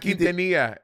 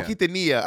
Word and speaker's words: Quintanilla: Selena, is it not Quintanilla? Quintanilla. – Oh, Quintanilla.